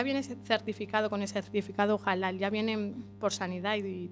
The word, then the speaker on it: halal: Lo digo, lo pronunciamos bien nosotros. Halal, carne, sí, halal.